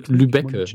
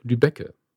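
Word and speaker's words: Lübbecke: Bojan Molin, tut mir leid, wenn ich es falsch ausspreche, Molichnik in Lübecke. Nicht Lübeck, Lübecke.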